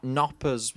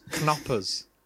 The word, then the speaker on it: Knoppers: But first thing I want to mention is it's not Knoppers with a silent K. It's Knoppers. – Yeah, they explicitly say Knoppers in the advert.